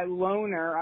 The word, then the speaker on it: Lonr: I saw a video, believe it or not, I actually saw a video that I liked by this guy, Loner. I'm assuming it's pronounced that way because it's like L-O-N-R.